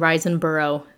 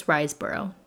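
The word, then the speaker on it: Riseborough: I kept saying Risenborough. It's Risenborough. There we go.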